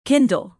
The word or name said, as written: kindle